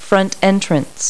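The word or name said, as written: front entrance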